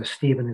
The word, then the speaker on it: Stephen: So Stephen is with a PH, not a V.